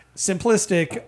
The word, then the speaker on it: Simplistic: Simp? No. Sim. Not simp. It's got dots in between some of the syllables, right? Like so. – Not simp. – It's not simp. Sim. List. There. Simplistic. And it's just like a nice 15% barrel-aged imperial stout.